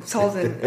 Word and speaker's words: Talzin: Well, he grows up on Dathomir as Mother Talzma's... – Talzin. – I can never get her name right.